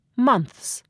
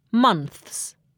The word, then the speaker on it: months: 以 th 结尾的单词变复数的时候，词尾需加上 s，所以在给 month 和 cloth 加上 s 后，读音就变成了 months 和 cloths，这是因为 month 和 cloth 都以轻辅音 th 结尾，也可以叫做齿尖音或摩擦音。字母 s 在轻辅音后的读音是 s，所以 ths 就读成 ths， months， cloths。